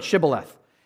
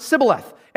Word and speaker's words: Shibboleth: Pronounce this word, Shibboleth. And because of where they had been raised and the area where they were, they could not pronounce the H in Shibboleth. They pronounced it Sibboleth.